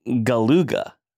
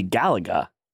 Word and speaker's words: Galuga: I remember seeing the preview for Contra Operation Galuga. It can't be Galaga. – That's not how Galaga is spelled. No. It is Galuga. Now, is it Galuga, like an old time car horn? – Oh, maybe.